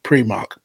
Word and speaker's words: Primark: The owner of the company said the way it's pronounced is actually Primark.